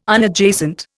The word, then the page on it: Unadjacent: Indian